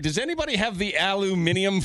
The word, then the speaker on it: aluminium: I went to school for chemistry, and that is how it's written in the periodic table, that is how it is pronounced in school. – Really? Aluminium.